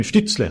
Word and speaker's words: Stützle: Stoltze. Eller Stoltze, eller hur uttalar man det? Tim Stützle skulle jag säga. Stützle, ja förlåt.